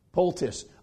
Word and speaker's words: poultice: It says that Isaiah made a, I don't know how to pronounce it, a polos, is that what you would say, call it? Of figs. What is it? Poultice.